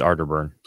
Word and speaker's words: Arterburn: uh, which is also a show hosted by Billy Ray Valentine, uh, Tony Arterburn, who you've shared the airwaves with, Atterburn. and Don Jeffries. Atterburn. no, it's Arter. It's Arterburn. Atterburn. No, it's Arterburn. I say Atterburn. You're saying it wrong.